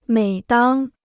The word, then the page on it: 每当: měi dāng